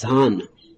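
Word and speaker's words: jhāna: In Pali, dhyana is pronounced as dhana.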